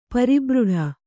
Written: ಪರಿಬ್ರಿಡ

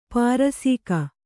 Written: ಪಾರಸೀಕ

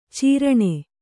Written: ಚೀರಣೆ

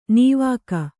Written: ನಾವಿಕ

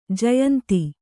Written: ಜಯಂತಿ

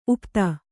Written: ಉಪಾಧಿ

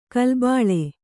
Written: ಗಲಿಬಿಲಿ

